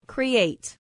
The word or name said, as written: create